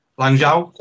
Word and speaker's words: Lanzhou: There's quite a few out there in, I think it's Lazoo, is it? Is that how you say it? Lazoo? – Is it Lazoo? – Lanzhou, something like that.